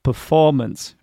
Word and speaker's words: performance: Okay, so here we have the word performance. Again, the first syllable has the schwa sound, so it is not stressed. We cannot say performance. We have to say performance, performance. Okay, right.